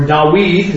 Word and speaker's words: David: Then David, or Dawid, is how you pronounce it.